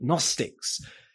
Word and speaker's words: Gnostics: There we are, the name you can impress your friends with here were Gnostics, spelt with a G, but Gnostics, who had some very highfalutin ideas about the sort of realm of the heavenlies.